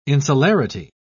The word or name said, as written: insularity